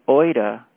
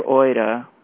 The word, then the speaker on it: oida: Here we have the, quote, oida, quote, kind of knowing. Okay, so the reference for oida is G1492, and that's O-I-D-A, oida, and the pronunciation is O-I-D-A,